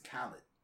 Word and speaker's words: Khalid: How do you pronounce the guy's name? – I think it's Khalid. – It's Khalid? – Mm-hmm.